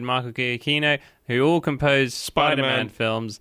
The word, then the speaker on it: Giacchino: It's Michael Giacchino. – So I didn't say it like that.